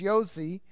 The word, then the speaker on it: Jose: as a short form of Joseph, Yosef, Yossi, and it's spelled like Jose. So in the Talmud, his name was spelled J-O-S-E, like Jose the Galilean, but it's Yossi, because there is no J in Hebrew. There's only Y, and Y is a J is pronounced as a Y.